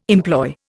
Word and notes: employ: Indian

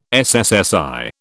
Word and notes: SSSI: British English

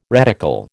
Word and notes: reticle: American English

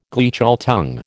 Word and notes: Gleichschaltung: American English

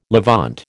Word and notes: Levant: American English

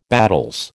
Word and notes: battels: American English

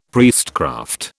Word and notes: priestcraft: British English

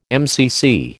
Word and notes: MCC: American English

